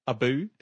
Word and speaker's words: Abu: Did you pronounce that right? – Apu. A monkey called Apu. His name was Apu. His name's Apu, isn't it? – Abu. – Is it Abu? – Abu.